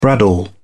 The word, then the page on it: bradawl: British English